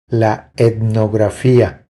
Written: La etnografia

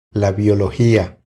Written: La biologia

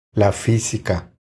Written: La fisica

